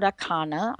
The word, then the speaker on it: Akana: But this is a fascinating story about Akana, I hope I said that right, yes.